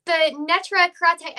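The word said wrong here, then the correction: Neturei Karta: and I'm so terrible about pronouncing that. – Natira Karta. – Natira Karta.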